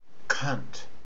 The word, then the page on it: cunt: Australian English